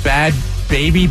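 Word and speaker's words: Bhad Bhabie: Isn't it just bad? Baby, but just bad baby. That's what it is. But there's H's in there.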